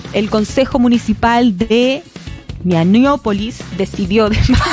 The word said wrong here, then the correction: Minneapolis: porque dice Ñañópolis. – Minneapolis. – Minneapolis. – Eso. – Maniópolis. – Monópolis. El Consejo Municipal de Minneapolis decidió desmantelar. – Minneapolis. – Lo dije bien.